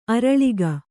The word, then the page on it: ಆರಾಲಿಕ: ārālika